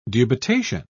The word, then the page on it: dubitation: British English